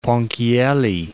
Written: Ponchielli

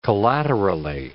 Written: collaterally

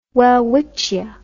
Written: welwitschia

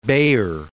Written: Baeyer, von

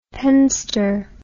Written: penster

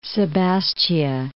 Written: Sebastia

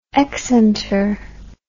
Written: excenter

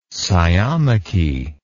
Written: sciomachy

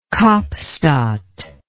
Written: kaapstad

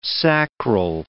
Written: sacral

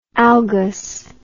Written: algous

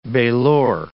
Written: bailor

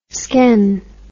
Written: sken

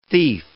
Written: thief

